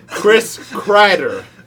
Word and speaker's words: Kreider: Number 4, Chris Kreider. – Wow, you said it right. Congrats.